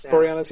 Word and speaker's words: Boreanaz: How do you pronounce his last name? Boreanaz? – Boreanaz.